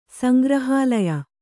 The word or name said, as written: ಸಂಗ್ರಹಾಲಯ